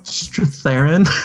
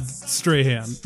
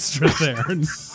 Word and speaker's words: Strathairn: Wait, Strathairn or David Strahan? – Strathairn. – Did he once play Edward R. Murrow? – Yes. – Yes, then it is not Strahan. It is Strathairn.